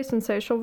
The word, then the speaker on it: syncytial: Thank you for bailing me out, because, you know, when I get into syncytial, I always want to add an extra syllable or something, and it doesn't come out right.